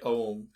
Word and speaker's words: Ogham: Sometimes you pronounce it, you hear it pronounced Ogham, I say Oam, it's O-A-G-H-A-M, but the H knocks out the G.